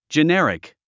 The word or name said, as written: generic